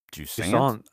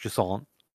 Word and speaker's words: Jusant: Jusant, I think. – Jusant? – Yeah, I think it's like French. Jusant. – Jusant. – It's spelled J-U-S-A-N-T.